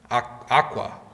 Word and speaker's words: Auca: Is that how you say it? Auca? Well, Aqua. Yes, I have a hard time saying that. Aqua.